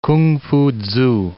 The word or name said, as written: K'ung-Fu-tzu